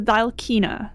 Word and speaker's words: Dhalkina: It's the Dhalkina. – Dhalkina. – The DH is pronounced nice. Kind of like somewhere between a D and a TH.